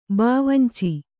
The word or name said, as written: ಬಾವಂಚಿ